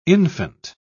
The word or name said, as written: infant